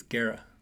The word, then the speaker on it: Guerra: My favorite that I listen to like kind of throughout the year is Lord Remind Me by Jon Guerrera. Is that how you say his name? – Probably saying it wrong. I think it's Guerra.